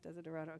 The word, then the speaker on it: Desiderata: Desiderata, okay.